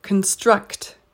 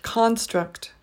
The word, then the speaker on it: construct: 11, the verb for this word is construct, construct. The noun is pronounced construct, construct.